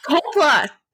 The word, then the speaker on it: Coppola: Nicolas Cage is related to Carmine Coppola. And like Francis Ford is his... Coppola! Dang it! – Coppola. – Coppola. It's like, you sound so dumb, Jin. You're saying it wrong.